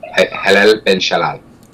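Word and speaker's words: Hallel ben Shalal (הילל בן שחר): Hallel ben Shalal is how it's pronounced in the original Hebrew. Hallel ben Shalal.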